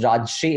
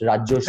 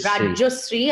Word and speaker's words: Rajyasree: He has told me that it's not pronounced Rajshri, it's pronounced Rajoshri. – Rajoshri, and I've told you many times.